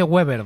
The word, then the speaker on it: Weber: No se dice Weber, ¿vale? – Sí, sí. – Se dice Manfred Weber.